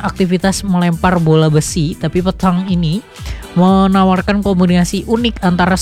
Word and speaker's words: Petanque: Namanya Petang Temu Pro 2. Tulisannya PETANQUE. Tadi hampir aja bacanya petangki.